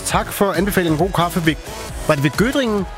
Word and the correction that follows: Göttingen: Nej, Göttingen. – Göttingen, ja, men det er... – Göttingen ost. – Jamen, det er bare mig, der ikke kan udtale det korrekt.